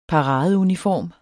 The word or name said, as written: paradeuniform